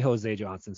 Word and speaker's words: Jose Johnson: No way, Jose Johnson. So that's how you say his name, just like no way.